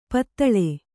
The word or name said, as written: ಪಾತಾಳಿ